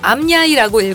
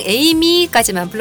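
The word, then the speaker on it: AMYAY: 20년 전 잘못 만든 아이디라 저도 그 어떤 누구도 읽어본 적이 없는 아이디를 제가 암야이라고 읽었더니 빵 터지셨대요. 그냥 에이미까지만 불러주세요 하셨어요.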